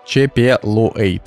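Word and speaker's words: Chapelwaite: Чепелуэйт. А, значит, э, его, причем он Чепелуэйт а, пишется через У, а, а в субтитрах Чепелвейт там написано, но ну, неважно.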